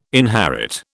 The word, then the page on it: inherit: British English